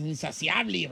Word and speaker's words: insaciable: Atascada para el sexo, pues, así como quiero más, es insaciable. – No me ¡dígame! – Se llama Daisy, mi querida. Bueno, entonces la palabra tágara es como insatiable, ¿cómo se dice? Insaciable.